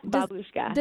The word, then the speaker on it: Babushka: Now, one of your characters was just so endearing to me, and I'm going to say it wrong, but is it Babushka? – Yeah, Babushka.